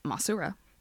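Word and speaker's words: Masura (モスラ): So Mothra, or the Japanese pronunciation, Masura, ooh.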